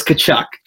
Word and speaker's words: Tkachuk: It's Tkachuk.